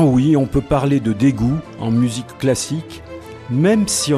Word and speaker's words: Daegu: Dégoût s'écrit D-A-E-G-U et c'est une ville de Corée du Sud, un peu l'équivalent de Lyon.